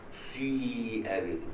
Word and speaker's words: سيئ: لوطا سيئ بهم، في القراءة الثانية بالإشمام سيئ بهم، سيئ بهم الإشمام إزاي تضم شفايفك وتنطق كسر، سيئ بهم.